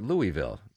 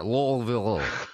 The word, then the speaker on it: Louisville: L-U-H-V-U-L-L. Think of that in your head and you'll pronounce it right. L-U-H-V-U-L-L. – To be fair, the reporter called it Louisville.